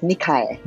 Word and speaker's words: Nikae: Last name is pronounced Nikae.